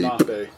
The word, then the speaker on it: Lenape: I've heard it pronounced both ways. It's Lenape and Lenape.